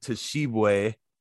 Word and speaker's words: Tshiebwe: And then, you know, he's going up against Oscar Tshiebwe. Did I say that right? – You did. You nailed that, man.